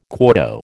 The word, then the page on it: quarto: American English